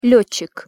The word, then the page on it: letcik: Russian